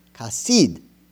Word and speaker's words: chesed: It's spelled C-H-E-S-E-D, but it's pronounced chasid, okay?